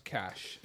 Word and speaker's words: cache: Cash, pronounced cash, according to the OED.